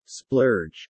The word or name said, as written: Splurge